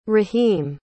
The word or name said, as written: rahim